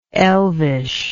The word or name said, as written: elvishness